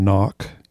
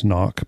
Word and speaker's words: Ngoc: I mean, we have a person that we've work a customer of, and she pronounces her name Knock. I think it's an anglicization. It's spelled N-G-O-C. – Yeah. – So she goes Knock because I think she wants people to have an easy pronunciation, but yeah.